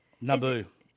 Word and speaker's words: Naboo: When they're at N-A-B-O-O. Is that how you... – Let me see it. – How you pronounce that? Let me see it. – Let me see it. Naboo.